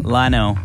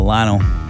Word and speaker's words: Lionel: How do you say his first name? – Lionel. – Okay. – Lionel Messi. – Right. Are you sure? – L-I-O-N-E-L, Lionel.